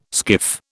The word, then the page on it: skiff: British English